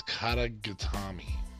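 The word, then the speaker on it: Kata-gatame: Anyway, the Katai Katami. Oh, it's spelled with a G. See, I was looking it up. So it's Katagatami.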